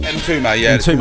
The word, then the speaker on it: Mtume: Well, how do you pronounce the band? Juicy Fruit. Mtume. – Mtume. Great song.